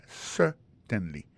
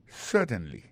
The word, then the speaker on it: certainly: Alors, si vous avez besoin d'appuyer, mettez plus Gévrine dans yes là, on dit yes, certainly. Alors, en parlant de accent, gardez bien pour un mot certainly, un accent tombé sur le premier syllabe là. Yes, certainly. T'aimes reprendre pour encore? Yes, certainly.